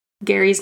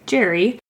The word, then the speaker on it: Gerry: While the pronunciation has changed over time, Gary's name is pronounced like I'm saying it, Gary, but I think we all know we now say it like Jerry.